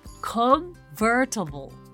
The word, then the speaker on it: convertible: comfortable あちょっと発音難しいですね convertible convertible car となります。